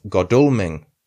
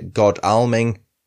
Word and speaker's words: Godalming: The first of which is the ancient market and coaching town of Godalming. I've researched the pronunciation. Most people apparently say Godalming, but apparently it's Godalming. Godalming, we'll go with.